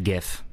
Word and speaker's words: GIF: The reality, I would say most people right now pronounce it GIF. – I say GIF because I don't care enough, honestly. – And that is the truth now. The truth is that it's pronounced GIF. And the whole GIF thing is a thing of the past.